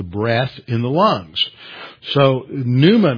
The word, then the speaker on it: pneuma: And every time you see the word Spirit, it's a translation of the Greek word pneuma. Pneuma has numerous meanings. It's spelled P-N-E-U-M-A, like you'd spell a pneumatic or pneumonia.